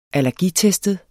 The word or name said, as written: allergitestet